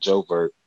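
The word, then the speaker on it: J'ouvert: He said Jovert, and I was saying Juve. Like, it sounded like Juve.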